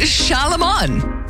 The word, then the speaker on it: Charlemagne: It's Charlemagne. Say it right.